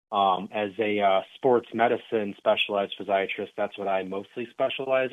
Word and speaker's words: physiatrist: What is a physiatrist? – Yeah, so actually you did pronounce that correctly. So most people actually mistake it for being a psychiatrist.